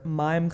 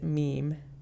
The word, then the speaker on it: mime: Diane, who thinks their plan is a bad idea, is otherwise engaged with her mime class, although she pronounces it meme instead of mime,